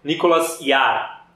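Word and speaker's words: Nicolas Jaar: Chama Nicolas o quê? Nicolas Yar. Nicolas, né, como se fala normalmente, J-A-A-R. Isso.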